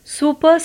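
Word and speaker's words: super: सो आर को pronounce मत कीजिए और बोलिए सुपर सुपर सुनने में ज़्यादा अच्छा लगेगा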